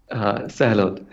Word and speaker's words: Sahalot: Sahalot. Hello, everyone. I'm Puneet Sahalot from PowerPack Beaver Addons.